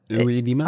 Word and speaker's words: oedema: Oedema? – I believe, yeah. Oedema. – Oedema. Oedema. – It's one word, and it is pronounced that way for swelling.